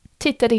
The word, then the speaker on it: tittade: Eh, om man uttalar tittade yeah. – men utan det, yeah. då säger man titta.